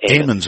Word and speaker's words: Amon: I mean, here you are, a priest, and you get a call, apparently, from, is it the Ammons family? Is that correct? – Amon's. – Okay, Amon's family. That's a long A.